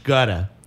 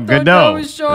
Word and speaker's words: Gadot: No, you pronounce it. It's French. It's Gada. – It's not French, it's Gadot. Jewish, man. – Gadot. Oh. It is pronounced Gadot.